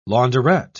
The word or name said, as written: launderette